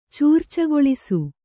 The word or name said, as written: ಚೂರ್ಚಗೊಳಿಸು